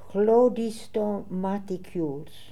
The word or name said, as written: Hlodistomaticules